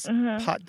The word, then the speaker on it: pâte: So it used to be spelled P-A-S-T, but now it's spelled P-A-T-E. But you translated the word P-A-T-T-E, which means paw or leg of an animal. – Yes. – So you translated, so you, it, they sound almost the same.